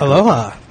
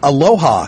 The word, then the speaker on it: Aloha: I kept saying, what is this town? Aloha. And they're like, it's Aloha.